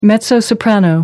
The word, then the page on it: mezzo-soprano: American English